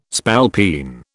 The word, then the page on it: spalpeen: British English